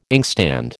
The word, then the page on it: inkstand: American English